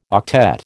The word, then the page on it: octet: American English